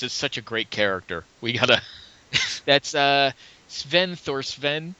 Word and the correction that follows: Sven Thorsen: It's Sven Thorsen. That's how you pronounce it.